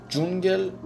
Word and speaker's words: جونگل: و خب حتی دی اس تی ها هم صدای ج میده، هیچ وقت تلفظ نکنید دش دش، این غلطه، صدای ج میده، مثل جونگل. جونگل با دی اس تی ها نوشته میشه ولی جونگل خونده میشه، به معنی جنگل، جنگلی که در مناطق گرمسیره.